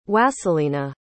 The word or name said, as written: Wasylyna